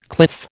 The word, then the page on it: cliff: American English